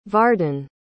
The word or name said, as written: varden